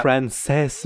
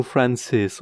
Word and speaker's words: Frances: Frances, nu Francis, ok? – Frances. Eu o pronunțam Frances, nu nu Francis.